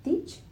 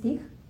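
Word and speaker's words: Thích: Algunos pronuncian el Thich como Tich, Tich Nathan eh, pero bueno, técnicamente según aprendí es Thich.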